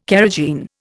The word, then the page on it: carrageen: Indian